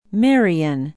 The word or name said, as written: marion